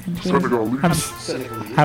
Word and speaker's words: Senegalese: I had Trace Senegalese. How do you say that? – Senegalese.